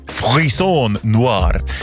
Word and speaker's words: Frisson Noir: Men det är något i stil med Frisson Noir som albumet ska uttalas.